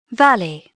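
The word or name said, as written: valley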